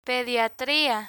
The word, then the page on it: pediatria: Spanish